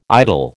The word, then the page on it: idle: American English